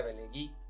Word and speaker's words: Velegui: ajá, Nosotros le pusimos V, pero es con B. – E, es, con B y se pronuncia Belegui.